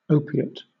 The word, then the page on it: opiate: British English